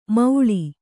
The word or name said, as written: ಮೌಳಿ